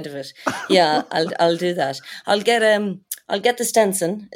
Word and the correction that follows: Stetson: Is that how you pronounce it? Stetson? – The Stetson.